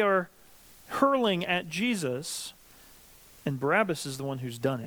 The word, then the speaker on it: Barabbas: So, Mark clues us into this fact, and then says the man named Barnabas had been imprisoned. Who's Barnabas? Sorry, Barabbas. I'm mispronouncing it. My apologies. Barabbas.